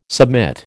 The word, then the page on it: submit: American English